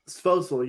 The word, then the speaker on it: Svozil: Svozil, yeah. Stanislav Svozil.